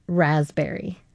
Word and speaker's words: raspberry: Raspberry. – Can you spell it now? – Yeah, because it's the silent P that got me. Why does it have a silent P? – You didn't get the raspberry. – No, I got B-E-R-R-Y.